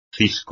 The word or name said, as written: cisco